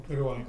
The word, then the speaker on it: Uruguay: When he's 22 in 1960, he was called to serve for a two-year mission to, is it, how do you say, Uruguay? Right? – Uruguay.